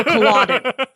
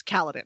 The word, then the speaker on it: Kaladin: And it was like, you know, we all generally said Kaladin. And I was like, "No, wait a second. You like the long A's, so Kaladin." And the look on his face was like, "No, it's Kaladin."